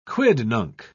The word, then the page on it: quidnunc: British English